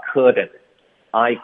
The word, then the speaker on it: couldn't: OK，所以大家张嘴发 couldn't，I couldn't。 Couldn't 的特我们就省略，导致一个停顿。